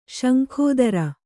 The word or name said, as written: ಶಂಖೋದರ